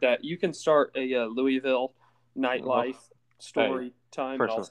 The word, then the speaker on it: Louisville: Louisville. – Oh, Louisville. Sorry. I can't Louisville. Start to pronounce it. Yeah. You got to pronounce it like there's marbles in your mouth.